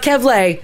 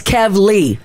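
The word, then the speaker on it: Kavleigh: Yeah. Because then I'd be like Kevlay. – It's not Kevlay. – But that's how I, it's Kevly.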